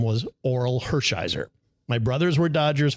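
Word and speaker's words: Orel: I could not pronounce his name correctly at all. Earl Hershiser. And I'm like, oh my God, what are you doing?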